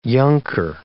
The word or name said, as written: younker